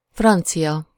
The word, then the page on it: francia: /ˈfrɒnt͡siʲɒ/